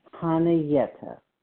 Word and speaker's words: Chanayetta: C-H-A-N-A-Y-E-T-T-A. And the C is silent. Kaniyeta.